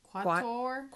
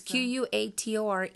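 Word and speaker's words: Quatore: Quator. Quator? – Yeah. It's Q U A T O R E.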